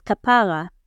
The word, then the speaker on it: kapara: The second slang word, though not directly found in the passage, is kapara. Pronounced emphasizing the second syllable, it is a term of endearment used for even the most casual of acquaintances.